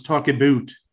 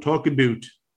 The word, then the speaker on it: Talk About: It's Canadian, so it's Talk-a-boot. – Oh, Talk-a-boot. – Yes, you got to say it like Alan. Talk-a-boot.